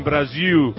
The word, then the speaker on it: Brasil: Ellos dicen Brasil, no como en Portugal que dice Portugal.